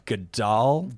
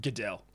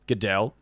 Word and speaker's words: Goodell: Now, I heard after last year's shit show with Maroon 5, Roger Goodell. – Goodell. – Goodell, thank you. Knew his name, didn't know how to pronounce.